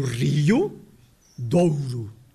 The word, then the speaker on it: Rio Douro: O Rio Douro, que é, claro, o Rio Douro. Rio, com duas sílabas, e depois com o ditongo.